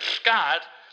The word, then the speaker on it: Skjodt: All right, and number five is John Calipari, a scared for Kentucky to play again at Simon Scott. I think that's pronounced Scott, S-K-J-O-D-T.